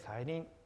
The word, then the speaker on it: 再臨: ここにはですね、再臨の約束と呼ばれる希望が書かれています。ちょっと難しい専門用語ですが、再臨というのは再び望むと書いて再臨と読みますね。